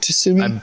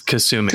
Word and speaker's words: Kasumi: Sasumi, is that how you pronounce it? Or is it Tsumi? – I believe it's Kasumi.